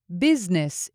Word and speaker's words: Business: The S is pronounced as a Z. These. These. Business is the same way.